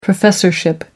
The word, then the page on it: professorship: American English